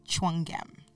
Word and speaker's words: Tchouanguem: Stacy Tchouanguem.